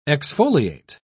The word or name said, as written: exfoliate